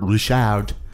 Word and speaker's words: Ri'chard: who's played by uh, Robert Ri'chard, uh, mm-hmm. which is how, how, the apostrophe in his name would indicate it's, how it's to be pronounced, and uh, who, who, has hauntingly beautiful eyes.